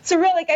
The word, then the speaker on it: Cyrillic: Cyrillic.